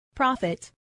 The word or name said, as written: profit